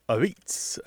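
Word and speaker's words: apizza: And so their particular dialect pronunciation of pizza, a pizza, which is, they say is a beets, which has that last syllable disappearing, is now just kind of a tradition in New Haven.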